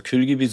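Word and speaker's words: Kül gibi: Kühlgibi war tatsächlich schon richtig ausgesprochen, ja. Also Kühlgibi, so wie das Wort im Türkischen sagt, versuche ich es jetzt mal ins Deutsche so gut wie es geht zu übersetzen.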